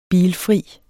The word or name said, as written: bilfri